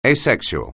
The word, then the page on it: asexual: anglais